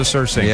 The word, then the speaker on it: surcingle: Around the middle was a surcingle. – Yep, exactly. – So for any of our audience who aren't familiar with this term, S-U-R-C-I-N-G-L-E, I believe, is the correct way to pronounce it.